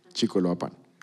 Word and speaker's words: Chicoloapan: Bueno, saludo a Ana Rosa que está de Chicoloapán, Estado de México. ¿Lo dije bien? Chicoloapan.